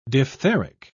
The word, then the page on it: diphtheric: British English